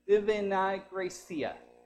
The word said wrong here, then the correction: Divina Gracia: and I probably totally butchered his name. Say it again. Divina Gracia, from the Philippines.